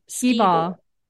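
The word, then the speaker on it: Skee-Ball: Am I pronouncing it right? – Skee ball. Yeah.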